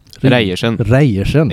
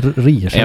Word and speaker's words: Reiersen: Uttalade jag efternamnet rätt? – Reiersen. Ja. – Jag säger Reiersen.